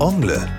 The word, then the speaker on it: Ongle: Das französische Wort Ongle bedeutet Zehennagel. Wird das Wort mit deutscher Betonung ausgesprochen, hört es sich so ähnlich an wie Onkel.